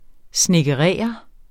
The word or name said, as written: SNEDKEREREDE